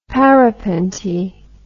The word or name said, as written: parapente